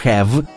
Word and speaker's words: have: «Но я думал», — сказал в свое оправдание ученик, «что «е» в слове «have» не читается». «Напрасно думали», — говорила учительница. «Это так называемое «э», оно не читается, но влияет на произношение предшествующего гласного».